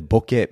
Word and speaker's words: bokeh: It is technically bokeh. We pronounce it bokeh for ease of use.